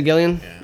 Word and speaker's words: Gillian: I'm not sure how to pronounce her name. Mm-hmm. And is it Gillian? – Yeah.